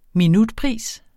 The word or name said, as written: minutpris